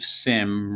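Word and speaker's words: Sime: McCaffrey's grandfather, Dave Sim, rhymes with rim. The E is silent. It's S-I-M-E.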